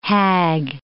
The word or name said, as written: hag